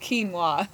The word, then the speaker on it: quinoa: It's spelled Q-U-I-N-O-A, so it's pronounced quinoa.